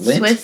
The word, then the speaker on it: Lindt: Is that how you spell it? Lind? Or say it? – Yes. L-I-N-D-T. – You just call it Lind? – The Swiss company, Lind. All right.